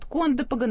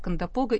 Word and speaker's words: Кондопога: И не удивляйтесь, что вот местные жители, да, они говорят «Кондопога» на первом слове, но словари, все русские словари говорят «Кондопога», и мы работаем в студии по словарям. Так что вот не нужно писать на пейджер про ударение.